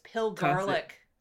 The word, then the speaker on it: pilgarlic: It's pill garlic this week. Pill garlic. That's apparently how it's said. I did go listen to about four or five different sites, and they all said pill garlic, so hopefully that's correct.